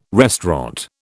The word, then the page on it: restaurant: British English